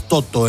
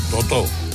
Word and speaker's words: Toto: ¿Tú cómo pronunciabas Toto en la radio? – Tal como lo has hecho tú. – Así insistió, Toto, ¿no? – No, Toto. – No, claro. No. – El Toto.